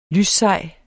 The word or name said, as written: lyssej